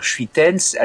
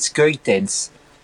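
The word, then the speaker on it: Schuiten: Et chez les Français, on aurait tendance à dire Schuitens, alors que c'est Skuitens. Voilà, le SCH se prononce que.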